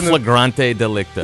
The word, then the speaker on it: in flagrante delicto: In delecto flagranto? How do you say that? It means in flagrante delicto.